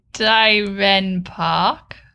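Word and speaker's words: Dierenpark: So this week in gay news, we're taking you to the exciting Amersfoort Jurens Park. – But sorry, I feel we need to like get a real true pronunciation of that. – Dieren Park. Zoo in the Netherlands.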